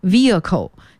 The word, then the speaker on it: vehicle: 那说起方向盘，我们也不得不再去复习一个词叫车辆，机动车，英文叫 vehicles， V-E-H-I-C-L-E， vehicle，机动车。那么这个单词 H 不发音啊，所以它不是要念成 vehicle，而是 vehicle，机动车。